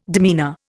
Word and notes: demeanour: Indian

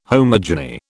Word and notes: homogeny: British English

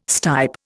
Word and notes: stipe: Indian

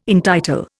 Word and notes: entitle: Indian

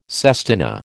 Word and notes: sestina: American English

